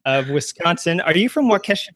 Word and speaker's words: Waukesha: It's, it's Waukesha. Wa- yeah, Waukesha. Waukesha.